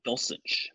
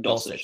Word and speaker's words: Dulcich: But I will say I think Greg Dulcich, am I saying his name right, over in – I think it's Dulcich. Greg Dulcich in Denver.